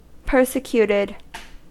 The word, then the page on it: persecuted: American English